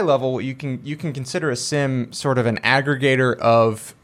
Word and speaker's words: SIEM: So today, we're talking about SIM. S-I-E-M. SIM, yes. with a silent E.